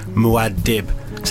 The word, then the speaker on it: Muad'Dib: Muad'Dib. Sounds like Madlib, the producer slash rapper slash DJ slash other stuff that he does.